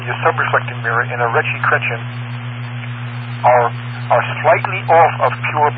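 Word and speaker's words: Ritchie–Chrétien: Because Hubble is not a simple Schmidt-Cassegrain, it's an RC, Ritchie Chrétien is the way they pronounce it.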